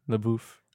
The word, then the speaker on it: LaBeouf: And we hear that pronunciation. – Yes. – French. French. – LaBeouf.